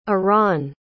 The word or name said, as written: arawn